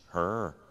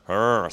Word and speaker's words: Hur: And so Aaron, his brother, and Hur, am I pronouncing that pro- correctly? H? Hur. Hur, sorry. Hur. Goodness. Thank you.